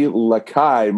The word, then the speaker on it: Lescaille: Our first guest is Mari Lakai.